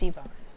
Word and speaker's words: Yvonne: My name is Yvonne. You spell it Y-V-O-N-N-E.